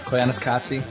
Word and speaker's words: Koyaanisqatsi: Koyaanisqatsi.